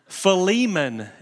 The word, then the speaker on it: Philemon: Philemon is the most used way to pronounce it.